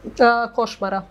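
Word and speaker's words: Košmara: But we did talk a little bit about, and she was telling me somehow that a little bit with the choice of Kosmara, is that how you pronounce it? – Koshmara. – Koshmara, mm-hmm.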